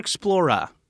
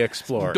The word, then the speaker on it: Explorer: Is it Explorer or Explora? – Dora the Explorer. – Dora the Explorer. – It gets off on a bad foot because it does ask your child to mispronounce Explorer.